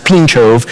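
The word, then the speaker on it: Pińczów: It's spelled P-I-N-C-Z-O-W. It's pronounced Pinchove.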